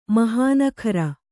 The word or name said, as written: ಮೋಹನಗಾರ